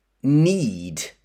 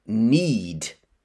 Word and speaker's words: knead: Next, a noun, loaf, L-O-A-F, loaf. And then another noun, dough, D-O-U-G-H, dough. And then a verb, knead. But listen to the spelling, K-N-E-A-D. So we have a silent K, need. And then another verb, prove, P-R-O-V-E.